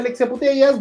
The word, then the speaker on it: Alexia Putellas: Alexia Putellas, así se pronuncia Alexia Putellas,